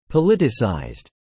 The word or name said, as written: politicized